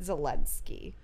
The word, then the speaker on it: Zelensky: So someone, one of the big things that have come up in the impeachment trials is that he's saying that he doesn't know President Zelensky. Is that how you say it? – Yeah.